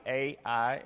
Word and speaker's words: Ai: Ai, A-I, is, and you pronounce it Ai.